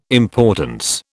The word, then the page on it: importance: British English